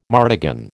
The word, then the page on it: martagon: American English